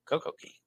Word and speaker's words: Coco Cay: Coco Key. Coco Cay. Royal Caribbean still pronounces it wrong.